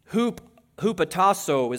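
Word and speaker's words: hupotasso (ὑποτάσσω): That word really truly means in the Greek, hupotasso is how you pronounce that.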